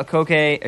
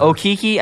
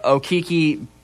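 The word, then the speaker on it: Okeke: So um, they win that game 77 71, even without Chuma Akoke Akike. Akiki? – Sure. Okiki, I think is how you pronounce it. Bryce Brown and Jared Harper took over the load. Of course, uh, Okiki tore his ACL in the Sweet 16 game against North Carolina, but Brown and Harper were tremendous.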